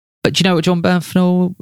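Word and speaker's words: Jon Bernthal: It obviously stars Will Smith and it stars John Bernthal. Bernthal. Oh, do you know what? I can't pronounce these. – John Bernthal.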